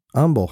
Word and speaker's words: Humble: Humble is pronounced with the H. But I pronounced it correct. – Humble.